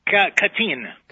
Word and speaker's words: Katyn: Is that how you say it, Katyn? – Katyn.